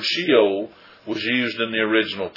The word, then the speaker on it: Sheol: As you go through, you will see that the Hebrew word that is rendered hell in the English is the Hebrew word Sheol, or Sheol, I've heard it pronounced, S-H-E-O-L.